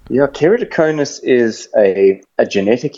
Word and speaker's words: keratoconus: It's called keratoconus. How's it? – Hey, Jan, you pronounced that perfectly. Well done. I don't hear anything vegetable about that pronunciation, so good one.